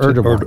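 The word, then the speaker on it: Erdoğan: President Trump said the only reason he was going, well, basically was for Erdogan, or how do you pronounce Erdogan. – It? – Erdogan.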